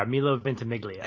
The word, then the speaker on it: Milo Ventimiglia: Yeah, Milo Ventimiglia.